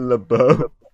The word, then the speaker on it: LeBeau: I said LeBlow instead of LeBeau.